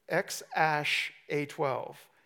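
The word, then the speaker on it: X Æ A-12: Elon Musk and his girlfriend, they named their daughter, it's pronounced X-Ash-A-12.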